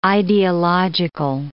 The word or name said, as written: ideological